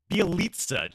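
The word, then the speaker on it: Bjelica: Bielica.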